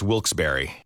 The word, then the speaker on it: Wilkes-Barre: that it was supposed to be pronounced Wilkes-Barre. Wilkes-Barre Scranton's 97.9X. The only way to pronounce Wilkes-Barre.